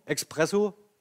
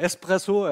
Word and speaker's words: Espresso: Ja, manchmal werden sie auch falsch ausgesprochen. Expresso, also naja, sei es drum, äh, Espresso, ja.